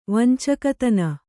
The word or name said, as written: ವಂಚಕತನ